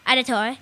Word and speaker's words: Auditore: Auditore. Yeah, that's pronounced right.